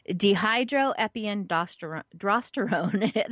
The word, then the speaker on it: dehydroepiandrosterone: It is, let's see if I can pronounce this correctly, dehydroepiandrosterone. Yes, it is drosterone. There is an R in there. So dehydroepiandrosterone, that is DHEA.